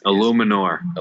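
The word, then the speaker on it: Eluemunor: Jermaine Ulemeyer is... – Illuminor. – Illuminor. Is that how you pronounce it? – I think so.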